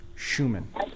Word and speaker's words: Schumann: I've been saying Showman forever. – Schumann. – It's Schumann. So if anybody's mispronouncing it, take the time and learn the German.